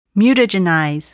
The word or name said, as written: mutagenize